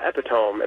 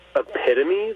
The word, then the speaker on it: epitome: And I said, "Do you mean epitome, the epitome of something?" And she goes, "No, no, epitome." And so I go, "How do you spell it?" E-P-I-T-O-M-E. And she goes, "Epitome." And I said, "Oh, my God, it's epitome.